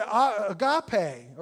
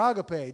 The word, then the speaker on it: agape: And so they got this word agape, or agape, depending on how you want to pronounce it.